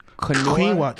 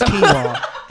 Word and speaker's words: quinoa: She got me eating like she got me eating like grilled chicken, asparagus, quinoa. See, I should definitely cut your mic. You're sitting here talking about Taco Bell. You can't even pronounce the healthy foods right. It's quinoa.